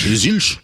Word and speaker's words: Zilch: Zilch.